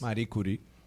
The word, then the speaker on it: Marie Curie: ¿Cómo se pronuncia eso? Marie Curie. Yo no soy ¿Eh? – francés. – Marie Curie. Así es.